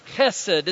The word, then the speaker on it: hesed: It's the word hesed. It's a Hebrew word. You might spell it this way, H-E-S-E-D, hesed.